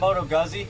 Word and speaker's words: Moto Guzzi: I had learned how to pronounce Moto Guzzi correctly.